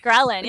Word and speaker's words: ghrelin: but I guess it was my gremlin. How do I say it? – Ghrelin.